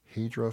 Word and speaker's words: Heidrea: And if I'm saying this wrong, I'm sorry, but I believe it's uh, for Heroes, H E I D R E A. Hydra for Heroes.